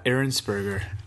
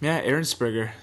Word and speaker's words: Ehrensperger: Ben, I never know how to say your last name. – It's Ehrensperger. – Ehrensperger. – Yeah. – Because I look at it, and I'm like, I know I'm going to mess this up. – Yeah, most people. Most people do. But, Ehrensperger. Right. Swiss German.